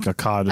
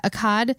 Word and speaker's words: Akkad: I think Akkad. Akkad. I think they're more of an ah sound. – Yeah, Akkad. It's A-K-K-A-D.